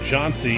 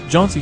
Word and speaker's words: Johnsy: On one last note, there's a debate on how the name Johnsy is pronounced, with some pronouncing it Johns. Bill Richardson gives us the background and another Hatfield-McCoy connection. – Johnsy Hatfield was named after a McCoy.